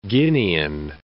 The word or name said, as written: Guinean